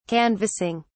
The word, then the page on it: canvassing: British English